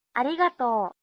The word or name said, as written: arigato